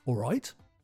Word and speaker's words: all right: Notice the pronunciation. It's very lazy. If I slow it down, it's something like "All right." We don't normally pronounce the L or the T.